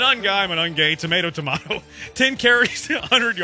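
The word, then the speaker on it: Monangai: Kyle Manunge. He went for That's not how you say it.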